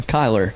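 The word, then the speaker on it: Kylar: Every time I look at that damn cup, E-R-I-N. Every single time. – It pisses me off. What's your name? Kyler. It's either Tyler, Kyle, or Skyler. It's never Kyler. Never once. – Get Skyler out of that. I mean. – And they always spell it with an A. S-K-Y-L-A-R. I'm like, no. It's K-Y-L-A-R.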